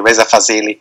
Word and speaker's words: Reza Fazeli: Is that how you pronounce it? That's how we pronounce it in Germany, Reza Fazeli. – Yes.